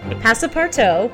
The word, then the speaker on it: Passepartout: Yes, you're playing the role of Passepartout, which we did look up the pronunciation before the show.